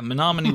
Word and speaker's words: Menominee: I believe this is Menominee? It's Menominee, Wisconsin? – It's a lot of vowels. – Yeah, Menominee, Wisconsin.